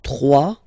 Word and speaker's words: trois: Trois. Final S not pronounced. Trois.